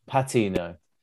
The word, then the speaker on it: Patino: Patino. – No, it's definitely a Plat, isn't it? – No, it's Pat. – It's Patino. – Pat. Oh, Patino. Okay.